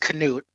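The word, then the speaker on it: Knute: I've always been fascinated with Newt Rockne, or properly pronounced Canute.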